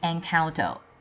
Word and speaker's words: Encanto: I realized that I pronounced it wrongly last week. Sorry about that, it should be Encanto.